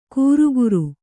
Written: ಘುರುಘುರು

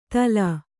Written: ತಲಾ

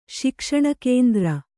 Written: ಶಿಕ್ಷಣಕೇಂದ್ರ

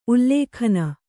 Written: ಉಲ್ಲೇಖನ

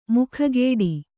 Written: ಮೊಗಗೊಡು

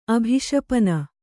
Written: ಅಭಿಶಪನ

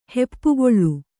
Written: ಹೆಪ್ಪುಗೊಳ್

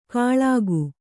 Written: ಖಳಗ

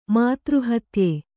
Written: ಮಾತೃಹತ್ಯೆ

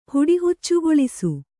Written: ಹುಡಿಹುಚ್ಚುಗೊಳಿಸು

